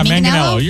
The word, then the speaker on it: Manganiello: Is it Manganiello? – Yeah, Manganiello. You're saying it right.